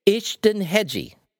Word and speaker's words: Ishtenhegyi: Jake Ishtonhedge. – Exactly. Perfect. – Yay! Ishtonhedge. – Yay!